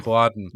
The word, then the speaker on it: Culloden: Culloden. I believe is how you say it.